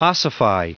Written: ossify

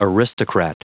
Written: aristocrat

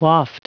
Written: waft

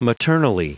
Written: maternally

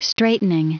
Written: straitening

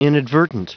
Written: inadvertent

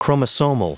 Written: chromosomal

